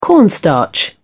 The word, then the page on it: cornstarch: British English